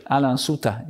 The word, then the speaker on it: Alain Sutter: Alain Sutter, genau, so spricht man richtig.